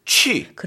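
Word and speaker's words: 취 (去): 다시 한 번 그 가다라는 발음을 해보면 우리 취리히 알죠? – 네. – 취리히. – 취리히. – 할 때 그 취 세 번 해볼까요?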